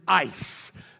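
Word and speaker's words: ice (εἰς): The word if in the original Greek Koine language that Paul wrote is the word ice. That's how you pronounce it, E-I-S. It's not I-C-E. E-I-S is the way it's written.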